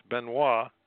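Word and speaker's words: Benoist: They flew on a flying boat designed by Thomas Benoit, pronounced Benoit, an aviation entrepreneur from St. Louis, Missouri.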